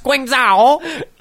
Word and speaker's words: Guangzhou: It's pronounced Guangzhou. – All right. My bad. It does sound better when you say it like Guangzhou. – That. Guangzhou.